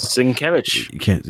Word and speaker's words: Sienkiewicz: Was the former Moon Knight artist, Bill Sienkiewicz? I'm going to butcher that. – Sienkiewicz.